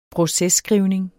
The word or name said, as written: processkrivning